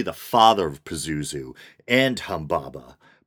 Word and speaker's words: Humbaba: Specifically, he was said to be the brother of Humbaba, kind of a funny word, sometimes pronounced or spelled Humwawa, even funnier, the monstrous guardian of the cedar forest in the Babylonian Epic of Gilgamesh.